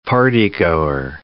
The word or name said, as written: partygoer